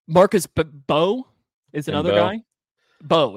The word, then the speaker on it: Mbow: It's just Bow. Like bow and arrow. There's no so, but it's spelled MBOW, but you don't say the M. So Bow.